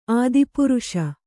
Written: ಆದಿಪ್ರಾಸ

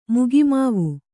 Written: ಮಗಿಮಾವು

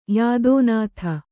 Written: ಯಾದೋನಾಥ

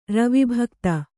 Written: ರವಿಭಕ್ತ

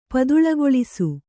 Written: ಪದುಳಗೊಳಿಸು

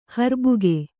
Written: ಹರ್ಬುಗೆ

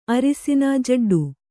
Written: ಜಿಡ್ಡು

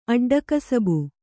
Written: ಅಡ್ಡಕಸಬು

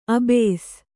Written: ಅಬ್ಬೆಸ

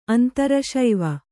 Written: ಅಂತರಶೈವ

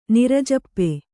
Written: ನಿರಜಪ್ಪೆ

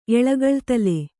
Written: ಎಳಗಳ್ತಲೆ

